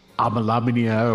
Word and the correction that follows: Abanasinia: I mean, how do we say it again, Adam? Anasia. – Anna. Abanasinia. Abanasinia. – Abanasinia. Okay, right.